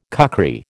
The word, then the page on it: kukri: American English